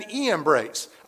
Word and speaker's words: Iambres: Strong says their names are pronounced Ianace and Iambres. I'm just not going to say that, okay? It starts with a J.